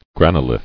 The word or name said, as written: Granolithic